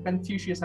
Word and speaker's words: Confucius: So it was Confucius. I don't know if I'm pronouncing it right. – Yeah, yeah, yeah.